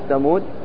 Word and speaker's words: ثمود: قال عند الوقف على كلمة ثمود تقف بحذف الألف وسكون الدال، وفي الوصل تقرأ وثمود بفتحة فقط على الدال، واضح؟ كلمة ثمود هذه أنت ولو أن الألف موجودة أنت لا تبالي بها من حيث النطق، من حيث اللفظ لا تبالي بها، فلا تأتي بها لا لفظا ولا وصلا.